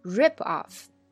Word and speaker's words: rip off: 那么 rip off 也可以做名词，做名词呢，重音就发生了变化。大家注意，这也是一个很重要的发音点哦。动词短语呢，很多都有的名词形式。那名词形式的时候，重音就跑到了前边，叫 rip off, rip off.